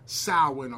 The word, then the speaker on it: Samhain: So Halloween's origin dates back to the ancient Celtic festival of, like you said, it's spelled Samhain, but it's pronounced Samhain or Samhain.